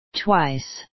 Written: twice

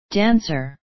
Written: dancer

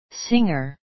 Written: singer

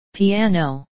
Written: piano

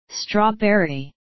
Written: strawberry

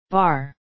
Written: bar

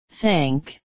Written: thank

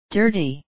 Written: dirty

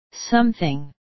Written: something

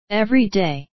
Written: every day

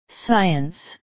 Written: science

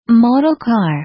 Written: model car

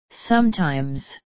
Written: sometimes

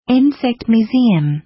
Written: insect museum

